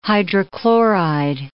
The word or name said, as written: hydrochloride